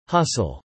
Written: Hustle

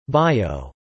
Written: bio

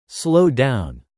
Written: slow down

